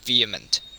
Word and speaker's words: VHEMT: 人类自愿灭绝运动，英语 Voluntary Human Extinction Movement，简称 VHEMT，发音为 vehement，直译是激越的意思。